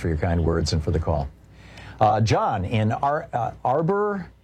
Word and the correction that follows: Auburn: California, am I saying it right? – Auburn.